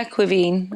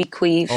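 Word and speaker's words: Caoimhín: We've always called him Quiveen. Is that correct? – Yeah, Quiveen, I would say. – All right. Because I know the goalkeepers call him Queeve. I was talking with John Achterberg the other day. Is that a different pronunciation of it, just so everyone's on the same page? – No, that's just the C-A-O-I-N-H, right. Would be Queeve.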